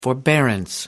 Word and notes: forbearance: American English

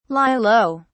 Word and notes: lie low: British English

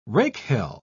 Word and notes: rakehell: British English

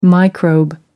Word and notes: microbe: American English

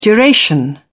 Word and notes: duration: British English

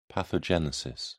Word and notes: pathogenesis: British English

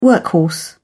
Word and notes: workhorse: British English

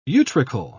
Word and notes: utricle: British English

